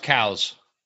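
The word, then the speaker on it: Coues: I think he pronounces them cows deer. – Yeah, that's proper. And check my history, but I think I want to say biologist or something that named the species or found the species, that was his name. – Yeah, and it's pronounced cows.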